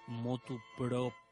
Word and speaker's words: motu proprio: Se dice grosso modo y se dice motu proprio. Proprio, pero esa corrección es muy difícil, repito, de pronunciar.